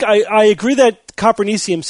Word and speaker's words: Copernicium: We thought it should have been Copernicum. – Copernicus, yeah. – But it seems that the proper pronunciation is Copernicium. – Copernicium.